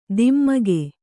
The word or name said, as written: ಧಿಮಿಕು